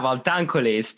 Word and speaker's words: Valtancoli: Yeah, Valtancoli. That's a good pronunciation.